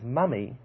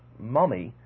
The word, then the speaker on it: mummy: It is pronounced as mummy and not mommy, which is the typical American pronunciation.